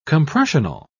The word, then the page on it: compressional: British English